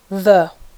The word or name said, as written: the